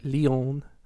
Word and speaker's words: Leon: And so Amicia and her father are out on a walk and with their beloved pet Leon. Is lion, but spelled in French. – Mm-hmm. Mm-hmm. – It's pronounced like a French person.